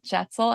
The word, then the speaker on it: Jetsel: It's Kyle Jetsel, and it's kind of like a pretzel with a J.